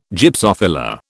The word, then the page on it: Gypsophila: British English